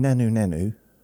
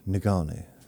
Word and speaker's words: Ngannou: I want to keep saying Nanu Nanu from Mork and Mindy. When Nanu Nanu, Ngannou. Got to be really careful how I say that word, that name. Ngannou is Ngannu, I think.